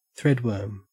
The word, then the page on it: threadworm: Australian English